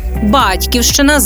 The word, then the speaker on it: Батьківщина: Батьківщина з малої пишемо також, коли мовиться про спадщину від батьків. Вимовляємо тільки з наголосом на першому складі.